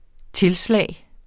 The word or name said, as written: tilslag